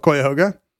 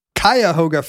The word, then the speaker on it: Cuyahoga: Did you know, by the way, that you can pretty much use any vowel for the first syllable of Cuyahoga? Webster's recommends Cuyahoga first, followed by Cuyahoga, schwa, yeah. – Cuyahoga, and Cuyahoga. – Cuyahoga. – So your options are I, ah, and O.